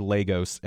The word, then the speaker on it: Lagos: Sticking around in Africa, over to Nigeria, though, this, I believe, the largest city in Nigeria, L-A-G-O-S. I want to say Lagos, but I think I've heard Lagos, and so I'm actually going to go with that long A, Lagos. – The long A is right.